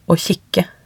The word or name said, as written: å kikke